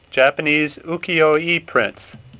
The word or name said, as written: Ukiyo-e